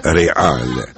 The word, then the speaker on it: ریال: ریال البته واژه‌ای است اصلا اسپانیایی به معنای شاهی، همان شاهی دوره سامانیان با تلفظ ریال، مثل تیم فوتبال رئال مادرید یا مادرید سلطنتی.